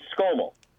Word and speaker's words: Skomal: Yeah, it's Skomal.